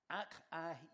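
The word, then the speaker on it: Achaicus: I am very glad that Stephanas, Fortunatus, and Achaia, why do they have these Greek names in here? Achaikos, that's how you pronounce that, have come here.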